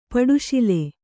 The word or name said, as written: ಪಟ್ಟಶಾಲಿ